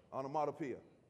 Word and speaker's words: onomatopoeia: But this word is what's called an onomatopoetic word. Hopefully I got that right. Onomatopoetic. Onomatopoeia. All right, okay. All right, we got. Thanks for straightening me out.